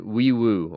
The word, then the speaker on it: Nwaiwu: It's Fabecchi and then Wee Woo. Almost, I mean, people have joked like an ambulance, but that's how you pronounce his name, the North Texas transfer.